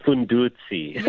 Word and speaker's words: Fundudzi: And you have a mantra to your clothing line, Fundudzi. Am I saying it right? – It's Fundudzi. – Yeah, you see, I intuitively knew I was messing it up.